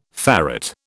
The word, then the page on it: ferrate: British English